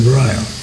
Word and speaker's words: Reul: It's spelled R-E-U-L. We pronounced it Royal.